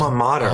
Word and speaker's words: alma mater: I always say it wrong. Altimator. Wait, what's your college? – Alma mater. Alma mater.